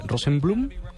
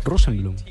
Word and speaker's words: Rosenblum: Se llama, él se llama Bruce, ¿cómo se pronuncia? Rosenblum. Rosenblum. – Sí, Bruce Rosenblum.